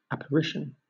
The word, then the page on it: apparition: British English